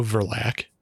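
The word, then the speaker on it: Verlaque: There's Jessica, who is kind of the main protagonist, and she's played by Nell Verlack. Is that how you say it? – Yeah, I would go Verlack.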